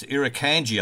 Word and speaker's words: Irukandji: And it's Irukandji, I think it's pronounced, isn't it, Gwen? – Yeah.